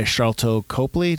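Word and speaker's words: Sharlto Copley: Go to a hospital to find Murdoch, who is played by Charlto Copley.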